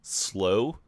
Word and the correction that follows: Slough: S-L-O-U-G-H? – Slough.